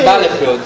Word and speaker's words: Battlefield: A pronúncia correta é Battlefield.